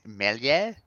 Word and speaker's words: Meslier: So goalkeepers, Ramsdale and Meslier. I'm going to say that wrong. I believe it's pronounced something different. Would you like to correct me? – I think it's Meslier. – Meslier, thank you.